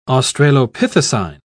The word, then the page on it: australopithecine: British English